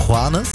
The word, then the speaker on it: Juanes: Da gab es nämlich diesen tollen Künstler Juanes.